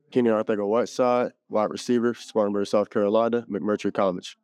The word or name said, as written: Keenan Arcega-Whiteside